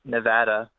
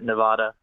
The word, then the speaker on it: Nevada: It's pronounced Nevada. We get pretty mad whenever someone calls it Nevada.